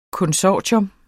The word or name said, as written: konsortium